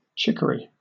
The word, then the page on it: chicory: British English